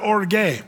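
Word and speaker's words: orge (ὀργή): But the Greeks would pronounce that orge.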